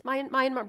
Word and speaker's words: Myanmar: In the countries of Burma and Cambodia, and Burma is now Myanmar, right? Did I say that right, honey? It's Myanmar.